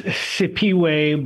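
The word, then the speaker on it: Siphiwe: The H is silent. It's Sipiwe Baleka.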